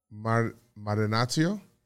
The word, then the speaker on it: Marinaccio: Marinaccio? – I like it. – Is that how you say it? – Marinaccio. – Marinaccio. – Yeah. Your C's got a little more.